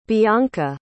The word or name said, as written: bionca